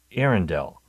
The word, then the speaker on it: Earendel: Arendelle, and that's spelled E-A-R-E-N-D-E-L. So Arendelle.